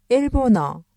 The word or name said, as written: 일본어